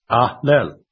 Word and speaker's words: allele: My wife presented a word to me this past week that word is spelled A-L-L-E-L-E. It is pronounced Ahlel.